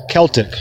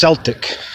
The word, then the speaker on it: Celtic: But one particular time, I used the word Celtic. C E L T I C. Celtic and w- the opinionated one goes, "That's you're saying that wrong." And I said, "What?" And he goes, "It's it's pronounced Celtic. pronounced Celtic." And I said, "Yeah, the, they call the basketball team that, and maybe you can pronounce it that way." But I was like, whenever someone talks about Celtic culture, they say Celtic,